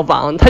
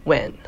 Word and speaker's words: 王: 后来我也去问了我说粤语的朋友，我说你们这个王是怎么发音？他们后来证实了，就是王这个东西，不管在哪个语言里面，它都应该叫王，它就不应该叫 Wan。只有在那个英语的人不知道该怎么读的情况下，他才会叫 Wan。所以。如果是我以后成为一个明星，那我自我介绍的时候，我还是要说我姓王。